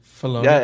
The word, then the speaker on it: Filoni: Filoni.